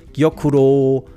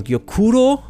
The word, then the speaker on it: Gyokuro: Gyokuro, Kabusecha, ich habe es falsch ausgesprochen. Gyokuro, Kabusecha, Sencha.